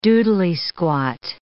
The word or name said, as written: doodly-squat